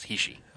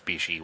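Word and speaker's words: Hishi (菱): Well, the actual pronunciation is Hishi. – Okay. – It becomes Bishi when you translate it.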